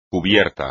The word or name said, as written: cúbico